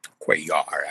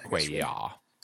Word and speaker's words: Cuellar: Henry Cuellar, is that how you say his name? – Mm-hmm. – In Texas 28. And I believe that Henry Cuellar won. – Cuellar, actually. – Cuellar. Okay. I